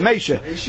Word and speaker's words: Mesha: This is not spelled Moshe and you pronounce it the Litvish Mesha. His real name was Mesha.